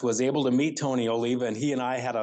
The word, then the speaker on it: Oliva: Yes, it's pronounced Oliva.